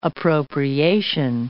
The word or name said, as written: appropriation